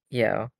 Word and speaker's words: Yeoh: I'm pronouncing it right, right? Yeoh? – Yeoh, yeah. – Okay, Yeoh.